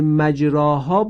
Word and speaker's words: مجراها: این کلمه مجراها باید اماله بشه. یعنی چه اماله بشه؟ یعنی اینکه حرف را در کلمه مجراها دارای فتحه هست. فتحه را هنگام تلفظ میل به کسره پیدا میکنه. ملاحظه کنید، میل به کسره پیدا میکنه، مکسور نمیشه.